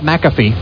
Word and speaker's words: McAfee: And they gave us a free McCaffrey at first when we first got it, and Hey, Tina, we're gonna teach you how to pronounce it first. It's called McAfee.